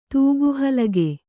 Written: ತೂಗುಹಲಗೆ

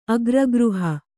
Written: ಅಗ್ರಗೃಹ

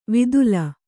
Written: ವಿದುಲ

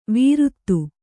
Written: ವೀರುತ್ತು

